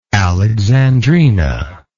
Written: Alexandrina

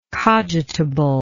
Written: cogitability

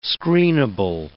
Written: screenable

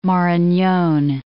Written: Maranon

